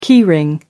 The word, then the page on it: key ring: American English